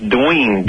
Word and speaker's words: Doyin: His name is actually, I believe, spelled D-O-Y-I-N, but it is pronounced Dween, D-W-E-A-N.